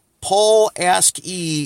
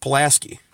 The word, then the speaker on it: Pulaski: It's pronounced Pulaski. We have a street here named Pulaski.